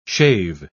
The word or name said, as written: shave